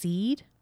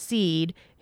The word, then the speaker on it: Sithe: And we also learn that, okay, there are different races, and he talks about the, I think it's pronounced seed. – Okay. – It's spelled S-I-T-H-E. – Yeah, I wrote that down too. That would be a very, like, Welsh almost pronunciation.